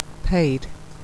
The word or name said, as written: paid